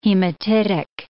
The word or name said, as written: hematitic